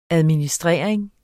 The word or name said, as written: administrering